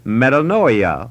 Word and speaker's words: metanoia: It is another Greek word which is pronounced metanoia or metanoio, and that means to change your mind or to think differently.